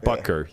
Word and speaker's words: Butker: So this past Sunday, I'm always going to butcher this name, Buttiger, the kicker for the Chiefs. – Buda Baker? – No. – No. – it's like... – Oh, Harrison Butker. – Butker, yeah.